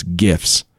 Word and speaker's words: GIF: GIFs with a hard G. I don't want to hear out of any of you f***ers. It's GIFs.